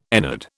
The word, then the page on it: ennead: British English